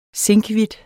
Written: zinkhvidt

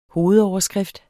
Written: hovedoverskrift

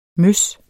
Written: møs

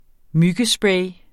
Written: MYGGESPRAY

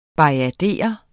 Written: BAJADERER

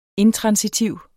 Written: intransitiv

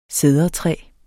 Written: Cedrus

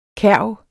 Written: kærv

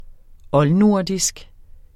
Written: oldnordisk

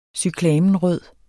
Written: cyklamenrød

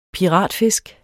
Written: piratfisk

